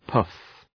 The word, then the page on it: poof: pu:f